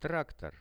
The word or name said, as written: traktor